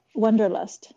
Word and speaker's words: Wanderlust: Alors, comment on prononce en anglais Wanderlust euh, Wanderlust, c'est ça.